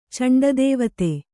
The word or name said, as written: ಚಂಡದೇವತೆ